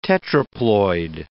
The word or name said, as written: tetraploid